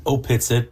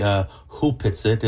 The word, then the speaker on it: Opitsit: It's written Opitsit, but it's Opitsit. – Actually pronounced properly Hupitsit.